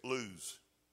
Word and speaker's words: Luz: And when you click on Luz, this is what it says lose. L O O Z E, lose is how it's pronounced.